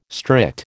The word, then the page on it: strict: American English